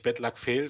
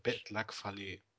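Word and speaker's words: Bad Luck Fale: Der heißt nicht Bad Luck Fail, sondern Bad, Bad Luck Fallet.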